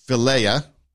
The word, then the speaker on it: philia: There's the philia, that's how you pronounce it, the philly, the brotherly love, the friendship, the kinship, you know, Philadelphia, city of brotherly love.